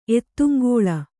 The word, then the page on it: ಎತ್ತುಂಗೋಳ: Kannada